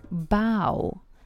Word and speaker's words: bow: B-O-W. B-O-W. Обратите внимание на произношение, потому что у этого слова есть другое произношение – bow, и это значит «бант». Но когда это глагол, он произносится «bow».